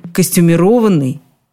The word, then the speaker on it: костюмированный: Словари ударений последних лет, и в частности словарь ударений Резниченко, прислушались к народному ударению и капитулировали. Они снова предлагают единственный вариант ударения, но уже другой – костюмировать костюмированный.